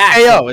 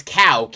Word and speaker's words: Kao: Cow, K-A-O. It's Cow the Kangaroo. – Yeah, it's K-O, isn't it? – No, it's Cow. Well, maybe it's pronounced K-O, but it's spelled K-A-O.